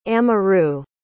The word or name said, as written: amaroo